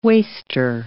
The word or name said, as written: waster